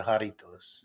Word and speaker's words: Jarritos: Jarritos. Am I saying that right? Jarritos? – The drink. Jarritos.